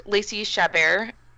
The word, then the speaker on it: Lacey Chabert: And I really liked Lacey Chabert.